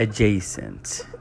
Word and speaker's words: adjacent: A pronúncia correta é adjacent.